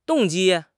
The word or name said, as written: 动机